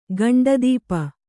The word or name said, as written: ಗಂಡದೀಪ